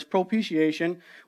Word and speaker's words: propitiation: Like I mentioned in John 2, it said that it said Jesus Christ the righteous, and he himself is the propitiation, essentially it's almost like pronouncing the word appreciation, but it's propitiation, which essentially means he is the atonement.